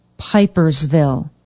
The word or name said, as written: pipersville